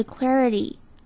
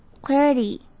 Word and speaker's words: clarity: 再来我们来看到 clarity 这个字， clarity 的 I 跟前面的 magnification 第二个 I 一样，要发成 a 的声音，然后它的 T 会像是介于 T 跟 D 之间的发音，整个字念起来就是 clarity， clarity。